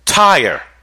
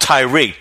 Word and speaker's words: Tyre: King Solomon commissioned the king of Tyre. I used to thought it was called Tyre, T-Y-R-E, but it's correctly pronounced Tyre.